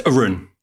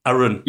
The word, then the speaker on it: Arun: For our audience, it is Aaron, isn't it? It's Arun. Arun.